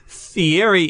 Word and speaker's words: Fieri: Fieri is supposed to be pronounced Fieri like you're doing, but I still think it's an elaborate cover because I don't think Guy can roll his R's like Italians can.